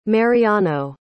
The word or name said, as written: mariano